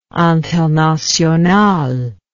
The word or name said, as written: internationale